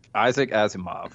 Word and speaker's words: Isaac Asimov: Anyway, so that's something – hey, if you want to look it up, A-S-I-M-O-V, Asimov's three laws – and I'm probably saying that terrible. How did you say it earlier? – Isaac Asimov. – There you go.